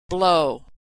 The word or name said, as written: blow